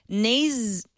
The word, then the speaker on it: Nasne: It's called Nasune. He actually phonetically spelled it for me. Thank you. N-A-S-N-E, Nasune, as in Neptune.